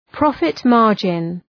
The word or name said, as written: profit margin